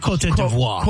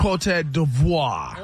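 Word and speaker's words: Côte d'Ivoire: Cote de l'Ivoire. – Devoir. – I think it's French. – Cote d'Ivoire. – Cote d'Ivoire.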